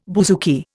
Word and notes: bouzouki: Indian

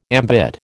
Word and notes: ambit: American English

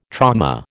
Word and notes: trauma: American English

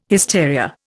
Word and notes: hysteria: Indian

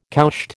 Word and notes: cowshed: American English

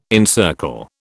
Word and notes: encircle: British English

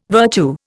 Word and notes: virtue: Indian

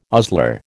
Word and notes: ostler: American English

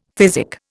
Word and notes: physic: Indian

